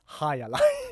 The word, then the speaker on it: Jai Alai: Och idag så ska vi prata om Jai Alai. Ja, eller som jag också insåg först igår, att det också uttalas Hai Alai.